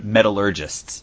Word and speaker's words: metallurgists: I have to look it up on my paper here because I kept saying it wrong. I kept say, saying metallologists. That's not a thing. – They're yep. called mm. – metallurgists.